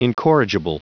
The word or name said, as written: incorrigible